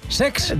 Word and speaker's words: Cesc: El Como de Cesc Fàbregas, que se estrenó... – Lo he dicho mal, es Sex.